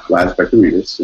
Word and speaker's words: Laz Vekiarides: So uh, so I'm Laz Vekiarides, and you pronounced my name correctly, so thank you.